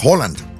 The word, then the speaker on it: Haaland: Que me dicen que no se pronuncia Haaland, es Holland. – Holland. – Holland. Nosotros le decimos Haaland porque viene sí. Pero es Holland.